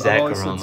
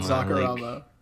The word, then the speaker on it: Zacarama: It's Zacarama. Zacarama. Like the name Zach, because my real name is Zach.